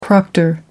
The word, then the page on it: proctor: American English